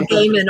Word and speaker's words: Gaiman: I've just heard Neil Gaiman all my life, you know, like I worked, when I worked in publishing and book retail, that's how the publishers pronounced it.